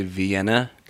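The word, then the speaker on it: Wien: Do you know how to say Vienna in Austrian slash German? – No. – Vien.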